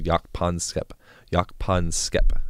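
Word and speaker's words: Jaak Panksepp: When rats are wrestling each other, there's a guy called Jakpan Skep is how you say his name.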